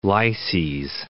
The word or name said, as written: lyses